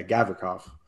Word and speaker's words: Gavrikov: I'd rather use Gavrikov is that how you pronounce it? Yeah. No, he's on the pair of Jones. Yeah, Gavrikov.